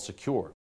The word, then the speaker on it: Sekur: That's why you need a new privacy and cybersecurity application tool called Secure. It is spelled S-E-K-U-R.